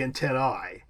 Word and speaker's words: antennae: In Latin, the correct pronunciation of an A-E diphthong would be an I, so it'd be antennae. However, in English, it's pronounced – Antennae.